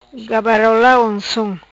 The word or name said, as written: Gabarola Oncom